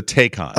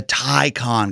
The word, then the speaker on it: Taycan: The Porsche one is the Taycan. – Oh my God! – Or it's the Taycan because I used to say Taycan as well.